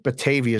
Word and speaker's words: Batavia: Is that right? I think Batavia is correct. That's how you say it.